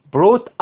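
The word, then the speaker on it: brought: اسمع كده، بيقول لك indeed حقيقي أو صحيح، نابليون اللي هو نابليون بونابرت ده اللي جيب الحملة الفرنسية بتاعته، بروت أحضر، بروت خلي بالك هتلاقي الجي اتش في وسط الكلمة ديت مش بينطقوا، بيسموهم سايلنت يعني لا ينطقون.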